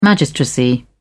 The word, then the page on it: magistracy: British English